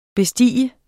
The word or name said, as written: bestige